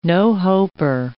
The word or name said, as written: no-hoper